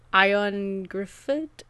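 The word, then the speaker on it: Ioan Gruffudd: And, actually the lead character, the guy who plays Henry, I'm going to butcher his name, but it's like Ion Griffith, which is Welsh for like John Griffith, but he is a Welsh actor and is fabulous.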